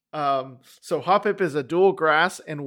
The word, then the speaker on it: Hoppip: So Hoppip. I think that's how you say his name. Hoppip. – Yep, Hoppip.